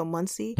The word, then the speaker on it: Muncie: And the, you know, the funny thing, when we stopped, when we got to Munchie or Muncie, Indiana, if anybody's out there and knows these places, we're sorry that we pronounced mispronouncing it.